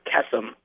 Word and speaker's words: Kesem: Am I pronouncing that correctly? – Yep, Camp Kesem, as in K-E-S-E-M.